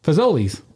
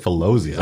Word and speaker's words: Fazoli's: Well, how do you say it? – Fazoli's. – You said Filosley's.